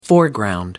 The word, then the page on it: foreground: American English